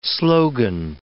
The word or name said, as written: slogan